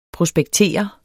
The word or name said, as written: prospektere